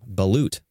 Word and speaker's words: Balut: B-A-L-U-T, right?